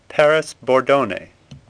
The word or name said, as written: Bordone